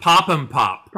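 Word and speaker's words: Pop 'Im Pop: Pop, I'm Pop, which I think is a hippity. – It's pronounced Pop'em Pop.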